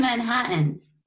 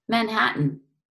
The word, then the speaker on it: Manhattan: How do you say M-A-N-H-A-T-T-A-N? – Machete? Oh, Manhattan? – You say Manhattan. – What do you say? – Manhattan. – What do I say? – Manhattan. – Manhattan. – Yeah, there's like a, you don't say the T.